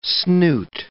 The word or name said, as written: snoot